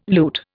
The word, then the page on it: loot: Indian